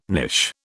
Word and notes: knish: British English

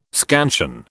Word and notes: scansion: British English

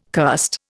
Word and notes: cursed: Indian

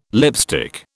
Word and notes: lipstick: British English

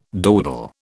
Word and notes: dawdle: British English

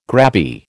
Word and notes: grabby: American English

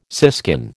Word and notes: siskin: American English

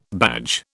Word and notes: badge: British English

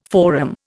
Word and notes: forum: Indian

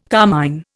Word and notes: carmine: Indian

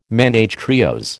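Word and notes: ménage à trios: American English

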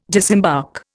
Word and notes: disembark: Indian

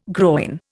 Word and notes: groyne: Indian

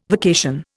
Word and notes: vacation: Indian

